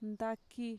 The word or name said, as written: ntaki